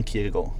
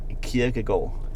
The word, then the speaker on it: Kierkegaard: Søren Kierkegaard. – Kierkegaard. – Yeah. – So it's almost like an L at the end? – It's a hidden D. You don't pronounce the D.